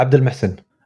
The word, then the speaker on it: Abdulmohsen: Yeah, Abdulmohsen.